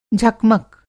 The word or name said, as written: झकमक